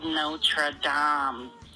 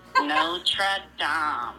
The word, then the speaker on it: Notre Dame: It's called Notre Dame, sonny. – Oh, my goodness. – You need to learn how to pronounce it correctly. Notre Dame. – Notre Dame.